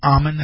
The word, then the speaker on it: Amen: Looks like Amen. Dr. Amen, that's the way to pronounce his name.